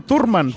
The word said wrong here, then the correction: Thurman: pero no, me estaba diciendo el caballo que se pronuncia Thurman. Oh, como con Z, sí, sí, más ah, o menos. Con Z, como Thurman. Con el eh, acento bostoniano, ¿no?